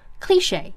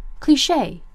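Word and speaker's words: cliché: cliche 这个单词的读音呢，在英式读音当中是重音在前，念作 cliche，美式读音的重音在后，念作 cliche。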